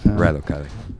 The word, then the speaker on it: Rilo Kiley: Because we listened to Jenny Lewis and Kylo Riley. – Take it all back then. – Rilo Kiley.